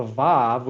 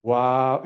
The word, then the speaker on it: Vav: Well, the Vav, which is like a W, right? Vav or Wow.